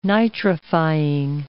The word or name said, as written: nitrifying